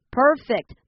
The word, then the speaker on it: perfect: 因为 perfect 当成动词的时候是念 perfect，它是使完美、改善、提高，basically means to make something better。